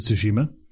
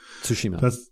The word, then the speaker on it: Tsushima: Which I'm actually correcting my pronunciation when I said Tsushima. – What is it? – It's Tsushima. Tsu. The Tsu phonetic doesn't exist in English, which means I'm almost certainly pronouncing it wrong. – Tsushima.